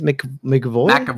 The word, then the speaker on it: McAvoy: James McAvoy.